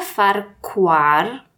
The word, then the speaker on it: Farquhar: Peter Farquhar, mam nadzieję, że jego nazwisko wypowiadam dobrze, jak nie, to sorry. – Wymawiasz tak, żeby każdy mógł sobie zgooglać. – Dokładnie. Nie, to by było Farquhar.